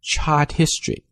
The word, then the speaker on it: chart history: 所以在读的时候你可以读成 chart history chart history chart history，直接就过来了，没有 H 声音。